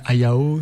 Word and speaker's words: Hayao: Je prononce bien Hayao, c'est... – Oui, tout à fait.